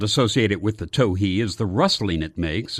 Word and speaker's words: towhee: The name towhee is supposed to be derived from the call notes of this bird, and therefore should be pronounced, probably, towhee.